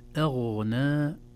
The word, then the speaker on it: أغنى: إذن أيضا 4 حركات، لكن كلمة أغنى، أغنى عنهم، الهمزة مرققة والغين مفخمة، لا نقول ما أغنى أغنى، لا، ما أغنى أغنى، نرقق الهمزة ثم ننتقل إلى الغين مفخمة أيضا لينة في النطق.